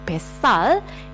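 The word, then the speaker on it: besal (뱃살): Jadi bacanya jangan dipisah menjadi bet sal, tetapi menjadi besal. Besal, pakai S double ya, jadi pelafalan S yang double itu harus terdengar.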